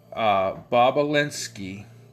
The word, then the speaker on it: Bobulinski: A former business associate of theirs named Tony Bobulinski. It's spelled B-O-B-U-L-I-N-S-K-I. Bobulinski. B-O-B-U-L-I-N-S-K-I.